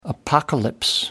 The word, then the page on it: apocalypse: American English